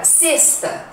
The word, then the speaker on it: sexta: Mas é assim, o X às vezes tem som de S. Por exemplo, na palavra sexta, sexta-feira, né? Sexto, sexta. Experiência, expectativa. Se escreve com X, mas o som é S, ok?